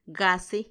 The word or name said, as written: gasi